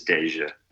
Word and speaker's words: Deyjah: But also, because I'm good at pronouncing names, D-E-Y-J-A-H is Deja.